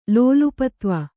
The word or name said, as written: ಲೋಲುಪತ್ವ